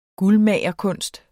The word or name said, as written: guldmagerkunst